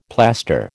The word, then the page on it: plaster: American English